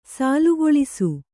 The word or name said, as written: ಸಾಲುಗೊಳಿಸು